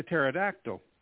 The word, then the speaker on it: pterodactyl: That's because if you don't hear anything, it's a pterodactyl because it's a silent P.